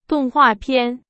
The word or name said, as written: 动画片